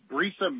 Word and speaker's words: Brisa: It's Brisa Max. It's actually misspelled in the district court record. The first word is Brisa, B-R-I-S-A. The second word is Max. And then the third word is Holdings, V-I-L-L-C is the full name of the company.